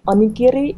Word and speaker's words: onigiri: You can if you look on YouTube and type in it, I think the Japanese pronunciation would be onigiri.